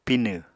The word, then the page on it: Pinw: Apatani